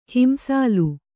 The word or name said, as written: ಹಿಂಸಾಲು